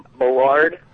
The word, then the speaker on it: Mallard: It's Mallard, French word for duck, actually.